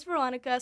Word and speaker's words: Veronika: As you can guess from the title, my name is Veronica, spelled with a K.